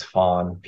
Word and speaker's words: Phan: So my birth name is Fawn, P-H-A-N.